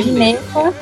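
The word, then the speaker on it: Ginetta: Como que se pronuncia corretamente? Gineta, Gineta, como que é? – Gineta, o correto é Gineta.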